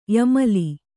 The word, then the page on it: ಯಮಲಿ: yamali